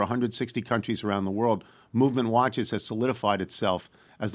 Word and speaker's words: MVMT: It's MVMT, pronounced movement. When you go to look it up, it's MVMT.